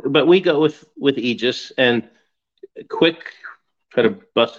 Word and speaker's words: Aegis: and not to correct you or anything, but there's two acceptable ways to pronounce it. There's Aegis and Aegis. And we go with Aegis, but I could care less as long as it's not something like, well, I don't even want to go down that road.